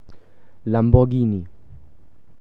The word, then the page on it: Lamborghini: German